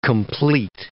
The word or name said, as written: compleat